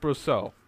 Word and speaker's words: Brousseau: Brousseau, is that how you pronounce it? – Mm-hmm. Yeah. – Brousseau.